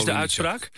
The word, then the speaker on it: Olenitschak: Jij zegt Olenitschak. Dat is Olenitschak. – Olenitschak. – De uitspraak. Olenitschak.